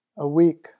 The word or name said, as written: a week